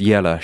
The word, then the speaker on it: Giela: The dog Beok barks, the goat bleats, the goose Grada honks, and the hawk Giela, pronounced Yella, shrieks.